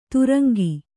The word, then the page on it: ತುರಂಗಿ: kannada